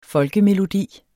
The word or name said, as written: folkemelodi